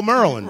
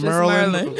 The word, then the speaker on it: Maryland: Maryland. – Maryland, right? – Yeah, like, if you say it like almost like Marilyn Monroe, Maryland. But with a D on the end. – Maryland.